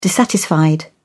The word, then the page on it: dissatisfied: British English